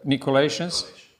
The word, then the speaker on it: Nicolaitans: So one is Nicolaitans, or whatever, how do you pronounce that? Nicolaitans? Yes.